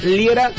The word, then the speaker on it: Liera: Y a mí me puedes encontrar por mi nombre, Fernando Liera, se escribe L-I-E-R-A.